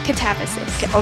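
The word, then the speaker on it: Katabasis: And as we said before, she's got all these other great books, Babel and the Necessity of Violence, Catabasis? Did I say it right? Catabasis? – Catabasis. – Okay. Okay, Catabasis.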